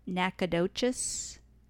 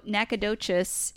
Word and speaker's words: Nacogdoches: They sing about it there, saying supercalifragilisticexpialidocious, because it rhymes. – That does help you say it, too. – It does. How okay, cute. So Nacogdoches is a sister city of the similarly named Natchitoches.